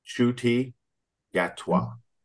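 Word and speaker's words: Ncuti Gatwa: I was like, I had to look his name up and say this properly. Shuti Gatwa is the new doctor.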